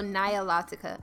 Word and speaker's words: Nilotica: Especially since you told me that the Milotica, I hope I'm pronouncing it correctly. You said Milotica? – Nilotica. – Yes. Nilotica. – Yeah, like so, from the Nile.